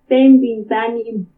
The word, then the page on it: Témbini ka tanimu: Purépecha